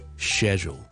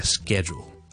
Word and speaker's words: schedule: schedule 或者 schedule， again British English schedule，美式英文 schedule， OK，英式英文 schedule，美式英文 schedule. Okay, don't forget the L sound at the end. A schedule or schedule with an L sound.